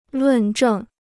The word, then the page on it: 论证: lùn zhèng